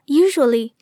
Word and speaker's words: usual: 先来听一听问题中提到的两个单词 usual 和 usually 怎么读。请我的同事 来做一个示范。 – usual usually 大家注意到了吗？ usual 和 usually 当中的浊辅音 r 这样读 r。